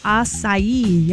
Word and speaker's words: acai: Anyway, the number one most mispronounced word is acai. Yeah, it looks like a K. A-C-A-I, an acai bowl.